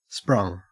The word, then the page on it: sprung: Australian English